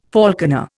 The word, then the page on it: falconer: Indian